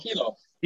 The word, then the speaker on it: Hilo: I'm sorry, is it the University of HELO or HILO? Am I saying it wrong? – HELO. University of Hawaii.